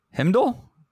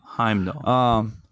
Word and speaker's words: Heimdall: Himdall? I can't wait till these losers lose, so I don't have to pronounce it anymore. – Heimdall.